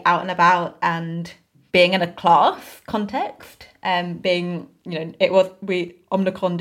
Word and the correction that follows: Omicron: didn't feel, is it Omnicron? I feel like Omicron? – I say it wrong each time.